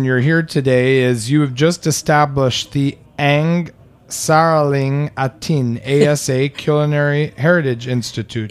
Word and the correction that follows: Ang Sariling Atin: It's Ang Sariling Atin.